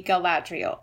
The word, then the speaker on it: Galadriel: I think I've heard most people put the accent on the second syllable, so it'd be Galadriel, but okay, god.